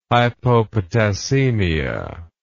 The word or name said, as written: hypopotassemia